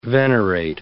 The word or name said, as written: venerate